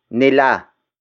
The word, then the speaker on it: nila: The next pronoun is nila. Nila. Nila. Nila is spelled as N-I-L-A.